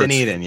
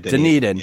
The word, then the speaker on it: Dunedin: Now you've got to go to Dundian. I always say it wrong. Whatever. – Dunedin, it's, yeah. – Dunedin.